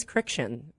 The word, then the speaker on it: Kriksciun: Yeah, mine's Criction. It's Criction. Just Friction with a K.